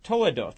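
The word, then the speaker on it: toledoth (תולדות): The word generations in Hebrew is pronounced toledoth, and it means descendants,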